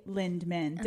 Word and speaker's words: Lindemann: Introducing Maggie Lindemann.